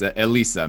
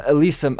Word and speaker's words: Elisa: What was it like finding Eliza? And I'm gonna probably butcher the last name, Menegal. – Meneghel, and it's Elisa Meneghel. – Elisa, I butchered the first name. Elisa Meneghel. All right, gotcha.